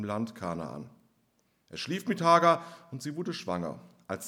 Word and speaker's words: Hagar: Sie hatte aber eine ägyptische Sklavin namens Hagar. Oder ich glaube, richtig ausgesprochen heißt es Hagar.